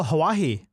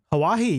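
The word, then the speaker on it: Huawei: It's a GSM hotspot made by, I believe it's, is it pronounced Hawaii? Or? – Oh, Hawaii. Hawaii, yeah.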